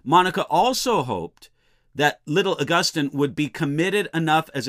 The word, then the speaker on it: Augustine: Sometimes people pronounce his name Augustine. If I pronounce his name during this lecture that way, I'll try to use the more commonly accepted Augustine.